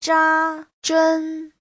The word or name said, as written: zha1zhen1